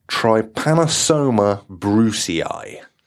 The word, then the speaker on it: Trypanosoma brucei: And this one, I'm gonna do a Josh now, because this one's quite a hard one to pronounce. Trypanosoma brucei. – Nailed it. That's exactly how you say it.